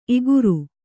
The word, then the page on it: ಇಗುರು: iguru